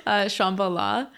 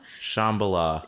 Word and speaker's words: Shambhala: Is it pronounced like that? I always assumed it was pronounced Shambhala, but is it... – Mm-mm. – No? – No. – Okay, how did you say it? – Shambhala. – Shambhala. – Yeah, yeah. I mean, I've heard it pronounced many ways, but I know that's kind of the standard.